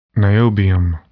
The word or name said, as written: niobium